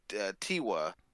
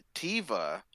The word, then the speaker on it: Tiva: So there was a lot of different, like, for instance, we're saying Tiwa, the owner, they actually pronounce it Tiva in the in the movie. You know, her name is pronounced Tiva. And I think the V is actually the proper way to pronounce it instead of Tiwa. It's pronounced Tiva.